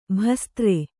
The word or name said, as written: ಭಸ್ತ್ರ